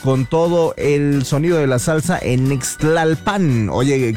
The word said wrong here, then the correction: Nextlalpan: casi rimó, casi a excepción del Nextlalpan, porque no se pronuncia así, es Nextlalpan.